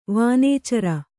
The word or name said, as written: ವನೇಚರಿ